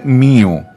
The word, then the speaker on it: mu: Όμως οπουδήποτε εκτός Ελλάδας και Κύπρου η προφορά τους δεν είναι όπως ίσως θα νόμιζε κάποιος εδώ. Έχουμε, έχουμε λοιπόν μου προφέρεται μίου και νου προφέρεται νίου ε,